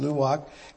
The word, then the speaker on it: Luwak: Kopi Luwak, sorry, that's how it's pronounced, Luwak,